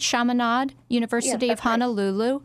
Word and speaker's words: Chaminade University of Honolulu: You went to community college and then on to, am I pronouncing this right? Chaminade University of Honolulu. – Right.